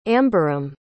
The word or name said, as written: amberam